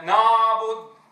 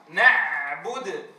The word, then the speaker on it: نعبد: نعم، هناك أخطاء شارعة تقع في حرف العين، أولها نطقها بصوت عائم أشبه بالألف بالألف المفخمة، كما يقول بعضهم نابد إياك نابد نابد، شو هذا نابد نابد نابد؟ صوت عائم شبيه بصوت الألف، وهذا خطأ في نطق العين، الأصل أن يكون الصوت محققا، نعبد نعبد.